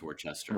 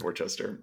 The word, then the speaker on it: Worcester: It's pronounced Worcester. – Worcester, yes, it's Worcester.